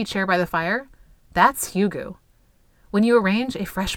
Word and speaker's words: Hygge: I had to look at how to pronounce it because it's spelled H-Y-G-G-E, but it's pronounced Hygge.